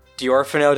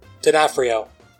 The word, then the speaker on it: D'Onofrio: And it has Rutger Hauer and Vincent D'Orfano. Did I say his name right? – D'Onofrio.